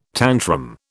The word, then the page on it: tantrum: British English